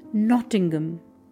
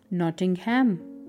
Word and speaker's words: Nottingham: The next city is Nottingham, that is often mispronounced as Nottingham.